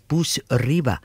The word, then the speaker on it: Bouzriba: That was called Zamen, Z-A-M-E-N. And it was a fabulous track, I thought, from Kaiser Busibra. No, Busriba. And that's spelled K-A-I-S-S-E-R. Busriba is B-O-U-Z-R-I-B-A.